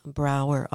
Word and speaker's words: Brouwer: It's um, Eve Brouwer, author, and my last name is spelled funny.